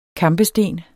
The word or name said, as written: kampesten